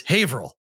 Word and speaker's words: Haverhill: Haverhill is how you spell it. It's Haverhill. Okay.